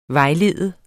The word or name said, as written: vejlede